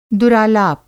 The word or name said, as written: दुरालाप